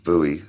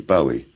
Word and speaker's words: Bowie: I always thought it was Bowie. I thought, well, it's a Scottish name, must be Bowie. But nobody in Scotland pronounces it like that. Pronounce it Bowie, I mean.